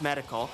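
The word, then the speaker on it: +Medical: Before that, from Medical, they actually have a plus, which is silent in front of their name, so it would be like Plus Medical, but it's like, it's just pronounced Medical.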